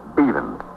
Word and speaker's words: Beavan: And your name is Myra Beavan? – I spell it B-E-A-V-A-N, but I pronounce it Beavan.